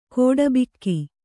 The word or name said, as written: ಖಟಬಾಕಿ